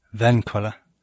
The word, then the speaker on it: Vinkola: Stephen Vinkola? Vinkola? How do you say it? – Vinkola. – Oh, yeah? Is that Dutch? – It's actually German, but been anglicized over 300 years in South Africa.